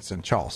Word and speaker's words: Senchal: I was really concerned whether or not it was Senchal or Senchal. Well, when you listen to the NPCs in the game, they all call it Senchal. So I was like, vindification, on my pronunciation.